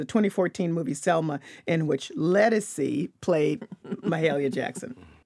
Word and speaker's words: Ledisi: I never know how to pronounce her name. – It's Ledisi. It's Ledisi. Okay, all right, it's Ledisi, who some people may know as an R&B singer.